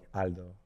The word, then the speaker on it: Aldo: Because when they ask my name, I just go like Aldo, like straight, like I was pronouncing it in Spanish, you know?